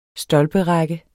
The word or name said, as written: stolperække